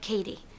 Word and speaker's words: Caddy: It's pronounced like Katie.